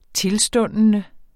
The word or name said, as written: tilstundende